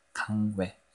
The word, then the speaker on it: 강외: 바로 양다나와 광해입니다. 강외 네, 발음이 좀 샜네요.